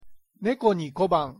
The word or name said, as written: 猫に小判